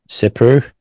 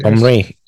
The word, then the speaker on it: Cymru: Cipru? Cipru? – Gymru, I think it is. – Cymru.